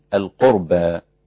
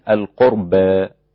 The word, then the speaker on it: القربى: كلمة القربى مقللة وممالة ومفتوحة، فهي بالفتح القربى، وبالتقليل القربى، وبالإمالة القربى، هكذا.